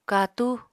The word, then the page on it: katú: Apatani